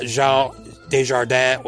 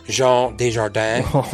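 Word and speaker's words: Jean Dujardin: I thought Jean Desjardins was really good. – Well pronounced. – Well, I took 6 years of French, so, you know, Jean Desjardins.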